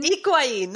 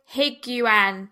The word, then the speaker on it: Higuain: Higuain. Not even the huh. Higuain. – Gonzalo Higuain.